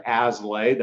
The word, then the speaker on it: Aslay: My name is Jonathan Aslay. That's spelled A-S as in Sam, L-A-Y.